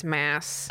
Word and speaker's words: Maas: It's Maas, right? – It's Maas. Oh. I've been saying it wrong this whole time.